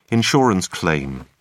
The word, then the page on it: insurance claim: British English